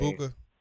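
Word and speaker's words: Egbuka: You got the guy from Ohio State, you know, Emeka Igwa. I can't pronounce his name. – Emeka Ibuka. – Thank you so much.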